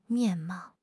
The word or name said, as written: 面貌